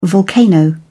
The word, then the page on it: volcano: British English